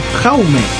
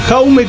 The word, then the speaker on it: Jaume: Un español que se llama Jean. – Aparte del nombre, ¿no? Jaume. Jaume Colet Serra.